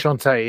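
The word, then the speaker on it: enchantée: So it says that it's a pop genre, La Parentes Enchante. Obviously, I'm saying that wrong because I'm assuming that's French. Is it French? – Enchanté, yeah. Enchanté. – Enchanté, there we go.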